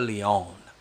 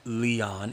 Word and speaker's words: Leon: And Leon is spelled like Leon, L-E-O-N, but if you're from Europe, you say Leon, especially if the accent's put in the right place.